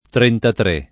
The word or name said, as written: trentatré